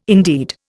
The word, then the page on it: indeed: Indian